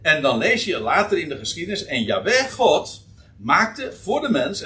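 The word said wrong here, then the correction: Jahweh: en voor zijn vrouw, uh, nou, ik moet de klemtoon anders leggen. En Jaweh, maar God maakte voor de mens en voor zijn vrouw klederen van vellen, namelijk van dierenhuiden, en bekleedde hen daarmee.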